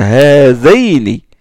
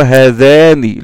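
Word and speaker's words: هذين: doit être prononcé comme ça, hazaini, et non pas hazani, le alif doit se transformer en ya.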